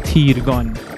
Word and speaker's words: تیرگان: بذارینم بگم تیرگان من اگه دوباره گفتم تیرگان باید ببخشید تلفظ صحیح این کلمه هست تیرگان مثل تیر ماهی.